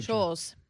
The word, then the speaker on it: Scholz: So the Chancellor Scholz, is that how you say his name? – I took Scholz.